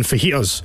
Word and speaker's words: fajitas: You don't pronounce the J in fajitas, apparently.